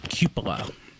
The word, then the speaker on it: cupola: It is Cupola. Not cupola or cupola, it's cupola.